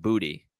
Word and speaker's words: Boutte: Oh, let's let's hit one more player, Boutte. Like he's big time polarizing. We talked about it. – Let's off. hit him in the Boutte. – Uh, actually, apparently, it's pronounced booty.